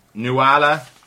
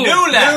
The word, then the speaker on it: Nuala: So Nuala is actually an Irish name. It's pronounced Nula.